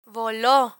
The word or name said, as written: volo